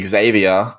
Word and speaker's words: Xavier: The fullback is probably going to be Xavier Savage, and I say Xavier because that's how he asked to have it pronounced, which I know is shocking.